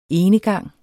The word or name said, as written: enegang